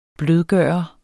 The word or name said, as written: blødgører